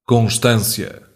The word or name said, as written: constância